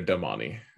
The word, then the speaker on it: damane: Oh man, that's kind of honestly, when I was reading it, I used to pronounce it mentally as domain, because that's how it's spelled, D-A-M-A-N-E. And the narrators have decided to call it Domani, and it drives me a tiny bit crazy.